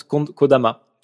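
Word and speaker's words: Kodama (木霊): T'as le kanji de l'écho qui se prononce Kodama.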